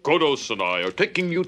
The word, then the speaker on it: Kodos: I'm talking Kang and, is it Kodos? – Yes. – Is that how you say it? – Yes, it is. Yeah, Kang and Kodos.